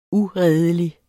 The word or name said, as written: uredelig